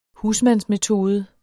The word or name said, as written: husmandsmetode